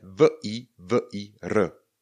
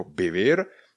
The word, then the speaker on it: vivir: Sloveso žít, píšeme v i v i r obě i něká. V i v i r vyslovujeme vivir. Vivir. Někdo vyslovuje spíše více jako bivir, někdo jako vivir.